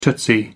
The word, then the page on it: tootsie: American English